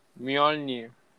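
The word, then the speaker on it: Mjölnir: Creo que lo dije mal. ¿Cómo es? Mjolnir.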